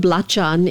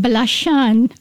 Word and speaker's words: Belacan: You know, the Hadrami forefathers called Belacan. – Belacan. – Belacan is like almost the Arabic pronunciation of Sambal Belacan.